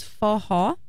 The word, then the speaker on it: forró: What we call the music is forró. It's spelled F-O-R-R-O, forró.